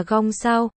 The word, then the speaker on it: Cung Thiếu: Cung thiếu, đọc là gong sao, gong sao. Chữ, sao, đọc gần giống với chữ thiệu là sao.